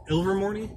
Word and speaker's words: Ilvermorny: Ilvermorny is the American Wizard School. – What's the name? – Ilvermorny? – Ilvermorny. – Ilvermorny. – Ilvermorny. – Just think like Ilvermorny, and it's pronounced just like that.